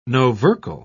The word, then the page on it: novercal: British English